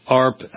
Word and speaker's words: AARP: In any case, it's just very nice to know that there is a group actually speaking for senior citizens, unlike the AARP, ARP, I guess as it's sometimes pronounced, almost sounds like a seal barking.